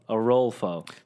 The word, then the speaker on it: Orolfo: Arolfo. So like you, like O. – O. – And then you roll a ball. – Roll. – And then fo.